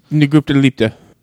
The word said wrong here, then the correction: Lupita Nyong'o: Lupita Nyong'o.